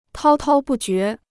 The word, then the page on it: 滔滔不绝: tāo tāo bù jué